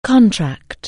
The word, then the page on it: contract: anglais